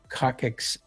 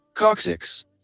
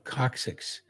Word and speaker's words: coccyx: He can get around on his own with pain in the coccyx area. Did I say that right? Let's see. – Coccyx. – Oh, coccyx. The coccyx area,